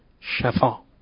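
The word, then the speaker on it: شَفا: دارم با فتح تلفظ میکنم خدمت دوستان، نه شفا، شفا به معنای اون طرف و شی، حاشیه تو شی، کنار و انتهای یه چیزی رو شفا میگن.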